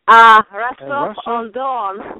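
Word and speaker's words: Rostov-on-Don: So now I've seen pictures of, I hope I'm pronouncing this right, Rostov on Dawn? – Rostov on Dawn.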